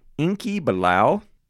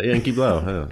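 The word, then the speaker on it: Enki Bilal: And that was conceptualized by a French comic artist, actually, by the name of Inky Bilal. that's just how you pronounce it, man. – Yeah, Inky Bilal.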